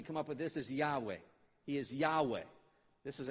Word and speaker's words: Yahweh: We pronounce it Yahweh. It has no vowels in the original pointings, the original text.